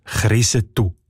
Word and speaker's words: Kriesental: Auf der Landeskarte ist der Hof in zwei verschiedenen Varianten angeschrieben. Kriesental und Krisetel. Und wie der Ueli Segesser schon herausgefunden hat, kommt der Name vor Ort als Krisetu ausgesprochen.